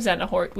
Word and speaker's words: Xehanort: He's the man of Xehanort. We confirmed this. – Mm. – That's how you say his name. – Xehanort confirmed.